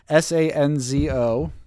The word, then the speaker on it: Sanzo: S A N Z O.